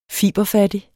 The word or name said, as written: fiberfattig